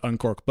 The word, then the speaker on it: Unqork: I think it's pronounced Uncork, but we can call Uncork if you want.